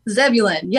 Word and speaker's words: Zebulon: Zebulon, Georgia? – Zebulon, yes. Most people think it sounds like a planet, but it is not.